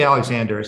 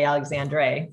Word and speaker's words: Alexandre: We say Alexander, it's just easier, but yeah, it's definitely spelled backwards.